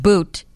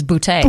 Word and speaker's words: Boutte: And there's the accent over the E, so you just make sure you don't pronounce it boot. It is Boutte.